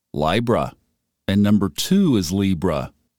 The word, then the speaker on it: Libra: And we scroll down to Libra, and it is pronounced Libra, I as in bind or time.